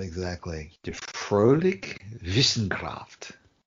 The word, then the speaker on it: Wissenschaft: Die Fröhliche Wissenschaft. – Say the last word one more time. – Wissenschaft. The W in German is pronounced V.